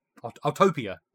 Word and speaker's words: Autopia: The how do you say this? Autopia? Autopia. There you go.